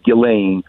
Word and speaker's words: Ghislaine: Well, so, you know, Ghislaine Maxwell, and I think the way they pronounce it, the S is silent, it's pronounced Ghislaine.